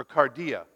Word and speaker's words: cardia: So, for example, the Greek word cardia means heart, or cardia, pardon me. Sorry, I didn't mean to mispronounce it.